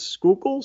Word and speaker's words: Schuylkill: I cheated and looked it up how to pronounce that, and I think it's Schuylkill, so you're extremely close, but I like the way you pronounce it better anyway, so Schuylkill, that's how it's going to be for this podcast.